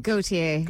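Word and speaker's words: Gotye: How do you say it? Gautier? Is that how you say his name? – Gautier. Yes, that's the man.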